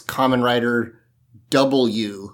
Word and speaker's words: Kamen Rider W: It is written out Kamen Rider W, and I found out that in Japanese it is Kamen Rider W, that is how it's pronounced.